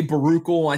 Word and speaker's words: Baruchel: Is it Jay Baruchel, I think is how you say his name. – Yes, that guy.